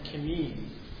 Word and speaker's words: kinnim: Now, the Hebrew word is, and forgive me if I'm butchering this, the transliteration is K-I-N-N-I-M, kineem.